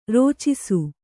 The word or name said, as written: ರಚ್ಚಿಸು